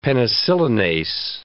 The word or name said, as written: penicillinase